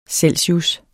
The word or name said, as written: celsius